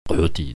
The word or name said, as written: kohoti